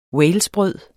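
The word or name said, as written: WALESBRØD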